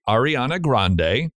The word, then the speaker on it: Ariana Grande: Ariana Grande.